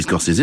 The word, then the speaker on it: Scorsese: Je dis Scorsese parce que j'ai entendu lui-même prononcer son nom ainsi ce matin, donc voilà, quand on aime la musique, on aime aussi la prononciation pas exacte, mais enfin c'est celle qu'il a choisie, c'est quand même son nom à lui.